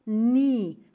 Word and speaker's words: nie: Se escribe N-I-E. Siempre que veáis este diptongo en alemán, I-E, es una I larga, como en diesel se dice diesel. Siemens no se dice Siemens, se dice Siemens, ¿ok? La IE es siempre I larga.